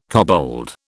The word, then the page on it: kobold: British English